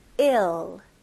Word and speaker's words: ill: 네, 발음은 또 I 발음이 나왔네요. 네, ill. 에도 아니고 이도 아니고 에입니다. 에 이렇게 ill 이거는 병든이라는 뜻을 가지고 있어요.